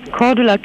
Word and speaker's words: Cordula: Cordula Kine. – Oh, wie schreibt man das? Cordula jetzt hier? – Mit C. – Zeppelin oder was? – Nee, C wie Cäsar. – Ja, weiter. – C-O-R.